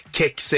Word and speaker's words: kex: Kex är det ljudlagsenliga svenska uttalet eftersom K före E blir förmjukat. Kex är ett, ett uttal som mera går på själva lånordet cakes va, som behåller den, det ljudet.